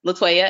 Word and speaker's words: Latoya: So it's uh, Latoya, L A T O Y A, and then Elnora, E L N O R A dot com.